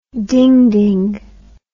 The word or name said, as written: dingding